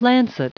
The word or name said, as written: lancet